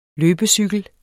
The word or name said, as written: løbecykel